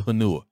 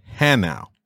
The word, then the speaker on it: Hanau: Where's the bus to Hanua? So they all kind of looked at each other like, this blank is crazy. And they was like, soldier, it is pronounced Hanau, and you missed your bus.